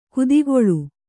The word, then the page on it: ಕುದಿಗೊಳು: kudiguḷu